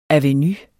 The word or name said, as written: avenue